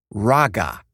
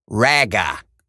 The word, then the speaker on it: raga: It's pronounced raga, not raga, okay? Not eh, eh, okay, not that. Well, here's another one. The Japanese word spelled like this, M-A-N-G-A.